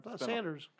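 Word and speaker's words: Sandurz: Yeah, he played Colonel Sundrews. Am I pronouncing that right? – Sanders. Colonel Sanders. – Is it Sanders? Yes. Okay, well, the way that they spell it is odd. It's spelled Sundruz.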